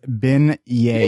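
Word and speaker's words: beignets: So it's pronounced bin yay.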